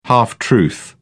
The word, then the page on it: half truth: British English